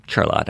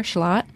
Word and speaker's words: Charlotte: I used to live in Charlotte, and I do put a little bit of an R in there, Charlotte. And she says, no, it should be like shallot, but with the emphasis on the lot. So there's no R at all.